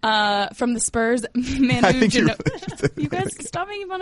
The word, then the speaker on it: Ginobili: Manu Ginobili. I can never pronounce his last Ginobili.